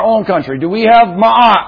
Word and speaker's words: Ma'at: Ma'at. All right, in English we would just spell that M-A-A-T, Ma'at.